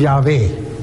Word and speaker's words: Yahvé: Pero de acuerdo a los lingüistas, de acuerdo a los eruditos, Jehová es la palabra menos probable. La más probable es Yahvé. Es la más probable, la manera más probable en cómo se pronuncia.